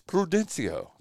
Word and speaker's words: Prudencio: It's Prudencio.